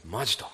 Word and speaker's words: 本気: この突然なんですけど、ベンさん、これを何て読むか分かりますか。本気と書いてマジと読みます、はい、本気っていう言葉、なんで書いたかっていうと、この真実にっていう言葉ちょっとピンとこないなっていうことで、なんか分かりやすい言葉ないかなっていろいろ考えたんです。